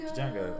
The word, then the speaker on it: Django: Django. – I think the O is silent. – The D is not silent.